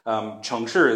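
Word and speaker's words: Chengshi (城市): Chengshi is how you say it in Chinese.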